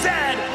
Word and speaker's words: zed: And it is pronounced Zed, not Z-Z.